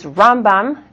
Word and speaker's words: Rambam: So the way that you can tell the difference is Rambam, the accent, is on the first syllable. Ramban, the accent, is on the second syllable.